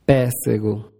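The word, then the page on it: pessego: Brazilian Portuguese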